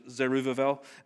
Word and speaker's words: Zerubbabel: It is spelt Zerubbabel, but in Hebrew, B is a V, so it's Zerubbabel.